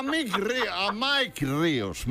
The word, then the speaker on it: Mike Ríos: A Mick Ríos. – A Mike Ríos. Mike. Es que tienes que pronunciar, bueno, hombre, tienes que pronunciar.